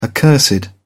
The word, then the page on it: accursed: British English